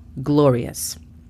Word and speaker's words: glorious: La pronunciación fonética sería G-L-O-R-I-A-S, con la pronunciación correcta de la letra R, glorious. La segunda palabra es gorgeous. Gorgeous significa espléndido o espléndida. La pronunciación fonética sería G-O-R-G-I-A-S.